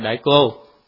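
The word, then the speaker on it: đại cô (大家): chữ này là đại chữ gia nhưng phải đọc là đại cô,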